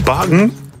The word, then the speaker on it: Bakken: Bagen? Zo wordt het dus blijkbaar uitgesproken in het Deens.